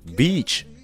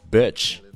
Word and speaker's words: beach: 但是在这儿你需要注意一下，b e a c h beach 沙滩这个词，你可不要读成 b i t c h beach。那 beach 的 e 和 beach 的 e 是一对经常容易出错的长短元音，所以说如果讲英文的时候不注意，是很容易闹笑话的。